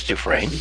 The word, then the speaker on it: Dufresne: I think it's Dufresne. – The S is silent, you see.